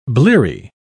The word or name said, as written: bleary